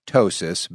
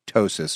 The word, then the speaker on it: ptosis: And there's lots of ways that we accomplish that depending on the severity of the breast Ptosis, big word, spelled with a P and then a T. So it's P-T-O-S-I-S.